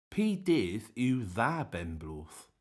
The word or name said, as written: Py dydh yw dha benn bloodh